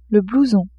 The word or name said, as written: blouson